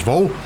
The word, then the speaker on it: Wohl: But yes, that is Billy Oliver with P.D. Vol, W-O-H-L, but pronounced Vol.